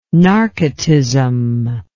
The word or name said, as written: narcotism